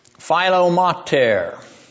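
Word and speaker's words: Philometor: Philomater, actually, better pronunciation.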